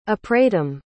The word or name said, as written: apratim